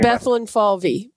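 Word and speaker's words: Bethlenfalvy: Can't pronounce his last name. – Bethlen Falvey. Peter Bethlen Falvey.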